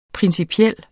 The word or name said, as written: principiel